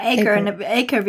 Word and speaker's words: Acre: Ja, Acre wird das ausgesprochen, das ist das englische Wort für Hektar.